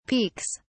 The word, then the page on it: peeks: British English